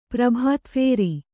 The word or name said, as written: ಪ್ರಭಾತಫೇರಿ